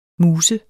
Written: muse

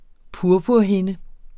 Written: purpurhinde

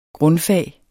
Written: grundfag

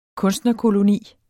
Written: kunstnerkoloni

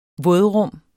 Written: vådrum

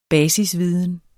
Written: basisviden

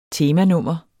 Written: temanummer

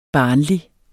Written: barnlig

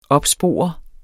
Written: opspore

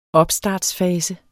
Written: opstartsfase

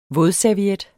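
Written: VÅDSERVIET

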